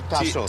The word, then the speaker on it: Taso: T-A-S-O, Tasso. – T-A-S-O.